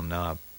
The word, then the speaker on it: knob: How do you spell knob? With a K or with just an N? – With a K. N-O-B-K. It's a silent K.